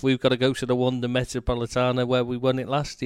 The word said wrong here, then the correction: Metropolitano: The Wanda Metropolitano.